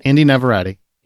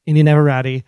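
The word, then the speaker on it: Inde Navarrette: Because I think Indy Navarrete I can't say her name. – Indy Navarrete. – Indy Navarrete. – Yeah.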